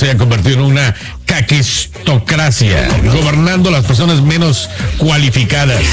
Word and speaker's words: caquistocracia: Así se pronuncia caquistocracia.